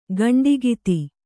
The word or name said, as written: ಕಂಠೋಕ್ತ